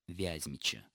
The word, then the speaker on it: Вязьмичи: Понятно, почему именно так ставится ударение в этих словах. Перед нами проявление тенденции, которая вообще-то характерна для современного русского литературного языка, тенденция сохранения ударения в производном слове на том же слоге, что и в производящем. Иными словами, если мы говорим «Вязьма», то логично предположить, что в Вязьме живут «Вязьмичи».